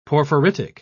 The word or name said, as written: porphyritic